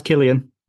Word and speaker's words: Cillian: We all know it's pronounced Killian. You got to get it right.